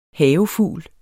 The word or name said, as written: havefugl